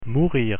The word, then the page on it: mourir: French